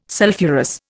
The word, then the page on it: sulphurous: Indian